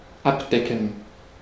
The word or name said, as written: abdecken